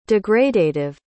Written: degradative

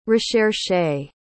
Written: recherché

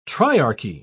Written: triarchy

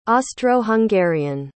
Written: austro-hungarian

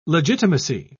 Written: legitimacy

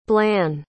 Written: blan

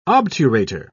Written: obturator

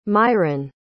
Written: Myron

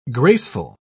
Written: graceful

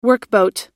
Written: workboat

